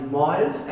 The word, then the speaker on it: Miers: My last name is Myers, and when I was in primary school, I used to trick, it's spelled M-I-E-R-S.